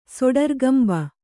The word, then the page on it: ಸೊಡರ್ಗಂಭ: kannada